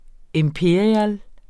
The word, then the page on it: imperial: [ emˈpæɐ̯ial ]; [ emˈpeɹiəl ]